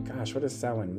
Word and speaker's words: Samhain: Like, that's a new perception I'm bringing into what Samhain represents. Sorry, I pronounced that wrong. Samhain represents.